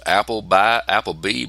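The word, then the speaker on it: Appleby: I think it's pronounced Apple B, but it's spelled Apple with B-Y at the end.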